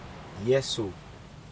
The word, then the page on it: Yesu: Aneityum